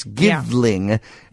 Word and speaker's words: Givling: Because it's Givling, and that is spelled G-I-V. L-I-N-G.